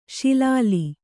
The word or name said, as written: ಶಿಲಾಲಿ